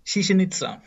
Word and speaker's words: Chichen Itza: Chichen Itza.